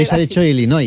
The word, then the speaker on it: Illinois: Se pronuncia Illinois.